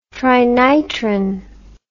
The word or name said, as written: trinitrin